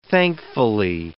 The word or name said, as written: thankfully